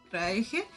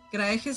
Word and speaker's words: Kraehe: Eh, si me quieren buscar en Instagram sería por Margot Craeje. Craeje se escribe K-R-A-E-H-E.